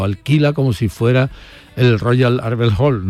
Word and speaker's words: Royal Albert Hall: No, yo creo que lo has dicho perfectamente. – Arbel, ¿no? Albert. Arbel, pues ya está, lo he dicho en andaluz.